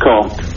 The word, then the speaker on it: Caen: And after D-Day, Montgomery was given the task of taking the very heavily defended German town of Caen in France, D-A-E-N, pronounced corn, and he failed to do so, mm.